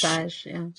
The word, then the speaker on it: dressage: Dressage. – Dressage, yeah.